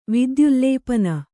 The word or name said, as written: ವಿದ್ಯುಲ್ಲೇಪನ